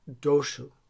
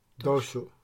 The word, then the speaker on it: Tossus: Okay, I'm going to try not to butcher this because it's spelled T-O-S-S-U-S. – Okay, but that's not how you say it. – So I said, Dev, is it Tosis or Tosis? And she said something like this, but I'm still probably going to butcher it. Doshu. Doshu. – Doshu? – Yeah.